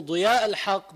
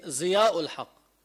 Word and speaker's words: ضياء الحق: فإذا واحد اسمه ضياء الحق بقولوا زياء الحق بقولوا عنه زياء الحق أسهلهم بالزاي فبيبدلوا الضاد زايا بس هو ليه لأنه الضاد صعب.